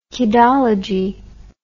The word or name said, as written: kidology